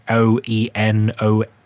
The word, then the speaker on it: Oenomaus: It's one of those Greek spellings, and it's Oenomaus. So Oenomaus, I think, is how you pronounce it.